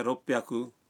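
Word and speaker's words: روپ بیاکو (六百): بعض آداد کا تلفظ کچھ مختلف ہے مثلاً 300 ہے سام بیاکو 600 ہے روپ بیاکو اور 800 کو کہتے ہیں ہاپ بیاکو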